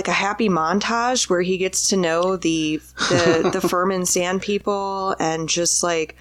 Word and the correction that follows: Fremen: Fremen. – You say Fremen, but they said... They pronounce it Furman every fucking time.